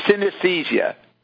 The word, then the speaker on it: synesthesia: Synesthesia? Or synesthesia? How do you say it? – Synesthesia.